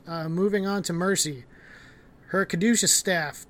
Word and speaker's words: Caduceus: It's Caduceus.